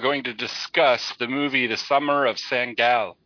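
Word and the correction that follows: Sangailė: Actually, it's Sangale.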